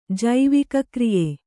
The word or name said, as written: ಜೈವಿಕಕ್ರಿಯೆ